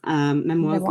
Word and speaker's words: Memoize: It's pronounced Memoize, Memoize London, and it is derived from the French word for memory.